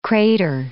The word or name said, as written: krater